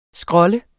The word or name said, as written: scrolle